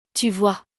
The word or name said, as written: tu vois